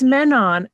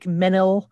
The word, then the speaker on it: Manon: it's Manon, and I only know this because after like 20 4 years. Oh my God, it's an old movie now. I finally watched it and I put on the subtitles for the first time ever because they pronounce it like 18 different ways. I thought it was like Menil, and but it's like it's Manon.